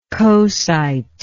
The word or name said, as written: coesite